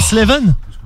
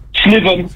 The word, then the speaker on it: Slewen: Ah, c'est S-L-E-W-E-N. – C'est ça, exactement. – Mais ça se dit comment, Sleven? – Sleven. – Ah, ok. – Sleven. – En fait, le W, tu le prononces V tout simplement.